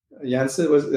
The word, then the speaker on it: Yensid: Yensid, am I saying that right? – Yeah. – Disney backwards.